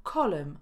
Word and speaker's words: column: Kolumna to column, a nie column.